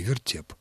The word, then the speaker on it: вертеп: А ударение на втором слоге «вертеп».